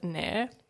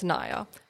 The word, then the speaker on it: Nair: Um, yeah, I think I had a bit of bit of an issue with that, just because like it's people always pronounce it Nair, and it's Naya, and like I don't mind people uh, making the mistake because I obviously like a, people say A I R and they think air, so Nair.